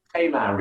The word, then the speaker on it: Tamar: But I'm not pronouncing it right. It's Tamar? No. Tamar. – Tamar Ridge. Okay. Tamar Ridge. Tamar Ridge.